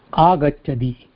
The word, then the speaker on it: आगच्छति: सह आगच्छति. – इट्स नॉट दी, इट्स सी.